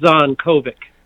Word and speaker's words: Zvonkovic: Did I say that right? – Almost, but you're not the first to mispronounce it, I'll tell you that. – Is it Zvonkovic? – It's pronounced Zvonkovic. – Zvonkovic. – Yeah. – Okay, and the first V is silent.